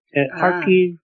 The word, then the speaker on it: Kharkiv: And when I say Kharkov, that is how it is pronounced, Russian. Kharkiv is Ukrainian.